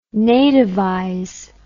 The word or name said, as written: nativize